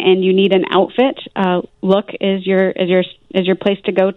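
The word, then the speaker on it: Lukh: L-U-K-H. I don't know how to pronounce it or what it is. – Yeah, it's pronounced Look.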